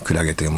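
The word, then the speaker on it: 海月: クラゲ、海に月と書いてね、クラゲと読むんですけども、クラゲという文字が力強くかっこよく見えたので思わず、撮ってしまいましたって、ね、写メが送られてきてんですけどもね。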